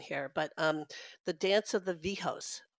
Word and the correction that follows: Viejos: is there a story behind that? – It's Viejos, means old man or little old men, viejitos.